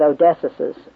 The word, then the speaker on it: Odysseus: And he begins by speaking of the Odyssey, book 19, and he deals with Odysseus' scar. For those of you who may pronounce it different, O-D-Y-S-S-E-U-S, O-D-Y-S-S-E-U-S, Odysseus' scar.